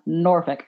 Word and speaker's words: Norfolk: So everybody either goes Norfolk, Norfolk, Norfolk, Norfolk, Norfolk, but no, it's pronounced Norfolk.